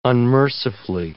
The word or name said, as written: unmercifully